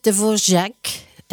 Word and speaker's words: Dvořák: Oui, en fait, Dvorak est un tchèque, et sur le nom qui est écrit avec un R, avec un circonflexe à l'envers, qui fait que le R est prononcé comme un J. Voilà pour la prononciation.